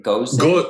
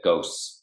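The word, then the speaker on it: Gose: I have guava, how did you say it? – Goze. – I just would have said ghosts, but yeah.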